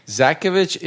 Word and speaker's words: Zakovich: Zakovich is the way we've been saying it for the past 30 years, or however long we've been in America, a little bit over 30 years.